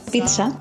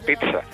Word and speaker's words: pizza: En principio es TS, ¿eh? o puede haber una cierta, o sea, sería pizza. No,